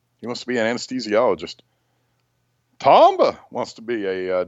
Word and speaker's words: anesthesiologist: He wants to be, I'm going to mispronounce this, an anesthesiologist.